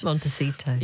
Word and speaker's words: Montecito: Montecito.